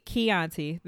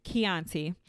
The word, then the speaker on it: Chianti: But this week we are drinking on a Da Vinci Chianti. – Chianti. – There we go. I was like, as soon as it came out, I know I said it wrong. Chianti.